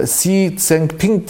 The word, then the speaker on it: Xi Jinping: E, Çin'le yaptığı görüşme bakacak olursanız e, Xi Jinping diye okunuyor herhalde adamın adı.